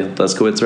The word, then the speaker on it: Leszkowicz: Did I say Leskowitz right? – Yeah. – Don't ask me to spell it. – I'm like the only person I've ever come across that has said it correctly.